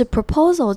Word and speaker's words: proposal: has unveiled 这个 unveiled 字首的那个 U 发成 E 短的 E 的音，然后 veiled 后面的这个 EI 发成长母音 A 的音 unveiled has unveiled a number of new proposals 这边的 proposals 的 P-R-O 的那个 O 发成 E 的声音，所以是 proposal。那要注意的是 proposal 这边有两个 O，那两个 O 发音是不同，第一个是刚刚说过的 E，但是第二个 P-O 的那个 O 发成 O 的音 proposal。